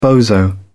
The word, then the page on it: bozo: British English